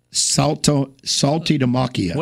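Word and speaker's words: Saltalamacchia: Saltalamacchi.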